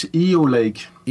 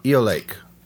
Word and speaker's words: Eolake: The last four letters in the first name is the English word lake, and the accent is on the E. So it's Eolake. – Eolake.